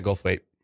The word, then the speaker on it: Goldthwait: Or is it Goldwaith? – Goldwaith. Gold. Bobcat Goldwaith. Wait. – Yeah, I can't say his name. – Bobcat Goldthwait.